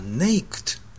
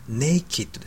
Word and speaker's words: naked: これはまあ、ご存知の方も多いと思うんですが、なぜ出したかと言いますと、発音がですね、 ed だから naked だと思っている人ですけども、 naked ですよね。これはどういうことかというと、 ed で形容詞、品詞が動詞ではなくて、形容詞の場合は id という強い発音なんですね。